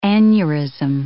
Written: aneurism

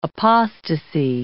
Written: apostasy

